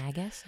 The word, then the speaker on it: Magus: Magus? Okay. – I believe it's a hard G.